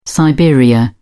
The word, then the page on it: siberia: British English